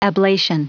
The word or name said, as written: ablation